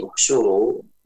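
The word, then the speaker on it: uhsyuru (اُحْشُرُوا): Ketika memulai dari uhsyuru, maka dimulai dari dhammah ya, uhsyuru, bukan ihsyuru. Hati-hati, bukan ihsyuru, bukan juga ahsyuru.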